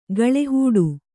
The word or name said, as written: ಗಾಳಿಹಿಡಿ